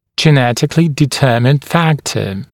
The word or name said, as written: genetically determined factor